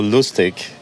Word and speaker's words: Lustig: So Lustig, that's the way we pronounce it actually, is the German word for fun.